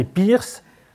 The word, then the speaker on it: Peirce: Je dis Peirce, mais ça s'écrit d'une façon différente.